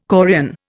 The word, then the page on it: chorion: Indian